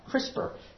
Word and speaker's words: CRISPR: It's a CRISPR. CRISPR is how it's said.